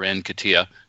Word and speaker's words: Nketiah: You know, let's give Nketiah a break, or Nketiah is my preferred pronunciation, but Nketiah break.